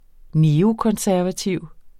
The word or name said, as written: neokonservativ